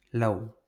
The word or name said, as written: lau